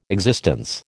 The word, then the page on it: existence: American English